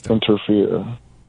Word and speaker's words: Enterfear: It's called Interfere. It's crazy. We're just talking about fear. But it's one word, and it's spelled like E-N-T-E-R-F-E-A-R. – Oh, Interfere.